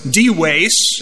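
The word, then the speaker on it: Dives: His name was D-Wace. Latin V is pronounced as a W-Wace.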